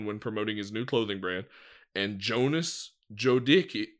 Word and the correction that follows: Jodicke: Jodicke? – Jodicke. – Jonas, J-O-D-I-C-K-E. I'm sorry for mispronouncing that. I think it's Jodicke.